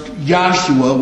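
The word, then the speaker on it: Yahshua (ישוע): In Hebrew, this is pronounced Yahshua, which we transliterate into English as Joshua.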